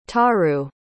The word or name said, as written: taru